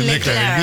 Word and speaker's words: Leclerc: Absolutamente, Leclerc. – Sí, Leclerc, bien pronunciado.